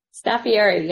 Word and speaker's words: Staffieri: Staffieri, yep.